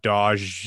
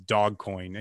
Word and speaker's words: Dogecoin: So all-wheel drive Civic situation, it's pronounced dog will, not Dodge or whatever you're saying. It's dog coin.